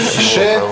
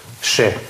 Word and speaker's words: chez: Значит, ШЕ и, скажем, заведение, типа кафе или бары у кого-то, да? ШЕ. – ШЕ. – Причем интересно происхождение. Это происходит от позднего латинского слова «каса», «дом». То есть буквально «в доме кого-то». То есть «я в доме кого-то» или «я иду в дом кого-то». Сейчас произносится «ше».